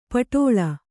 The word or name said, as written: ಪೆಟಲ